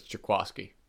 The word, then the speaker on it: Jaquiski: You pronounce it like everyone else, but it's Jaquiski.